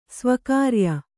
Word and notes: ಸ್ವಕಾರ್ಯ: svakārya

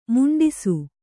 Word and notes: ಮುಂಡಿಸು: muṇḍisu

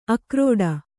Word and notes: ಆಕ್ರೀಡೆ: ākrīḍe

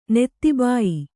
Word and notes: ನೆತ್ತಿಬಾಯಿ: Kannada

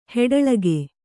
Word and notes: ಹಿಟ್ಟಳಿಕೆ: hiṭṭaḷike

